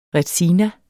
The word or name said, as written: retsina